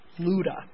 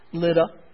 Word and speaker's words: Lydda: In the Greek, it's pronounced Luda, but it's Lydda.